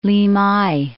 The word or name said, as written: Limay